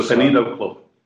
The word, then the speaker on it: Sonido Club: Um, it's like Sonico Clube. – Sonido, Sonido Club.